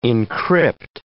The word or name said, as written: encrypt